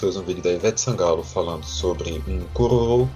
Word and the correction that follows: caruru: que é caruru, que é um prato...